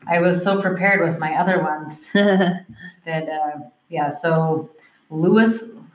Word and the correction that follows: Lois: I'm going to say that wrong. Last name wrong. Lowry. – Oh, Lois Lowry. – Lois. – Yeah, okay. Lois.